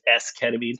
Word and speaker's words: esketamine: Talk to me a little bit about your research with esketamine. Is that how I'm saying it right? Esketamine? – Yeah. You're saying that exactly right.